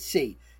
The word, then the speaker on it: Tse: She spells it T-S-E, but I think they pronounced it C.